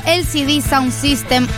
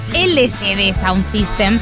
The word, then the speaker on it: LCD Soundsystem: LCD Sound System, que como le suelo decir yo, Em, pronunciando lo que son las primeras tres letras en español y no con la pronunciación correcta, que es en inglés,